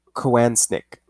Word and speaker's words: Kowansnik: Assistant Prosecuting Attorney Steve Kowansnik. Oh, I'm sorry, that's like a weird last name. Kowansnik. Yeah, that's how you say it.